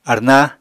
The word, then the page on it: arná: Apatani